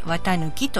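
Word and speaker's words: 四月一日: でこの綿抜きなんですが、漢字は抜くという漢字は貫くという漢字を書いて綿抜きと言います。そういえばですね、東北地方に 4 月 1 日と書いて綿抜きと読む名字があるそうです。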